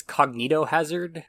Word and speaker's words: cognitohazard: Oh, I'm just gonna say, I think it's cognitohazard, or at least the cognito part, that's how it's pronounced.